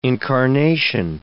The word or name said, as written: incarnation